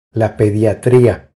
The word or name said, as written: La pediatria